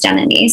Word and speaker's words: Janani: Ramachandran is not that hard, nor is Janani.